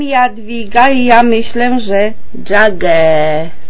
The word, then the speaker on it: Geauga: I think it must be Geauga County, because that rhymes with Cuyahoga County, which is right next door.